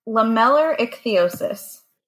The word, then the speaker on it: lamellar ichthyosis: Lamellar ichthyosis.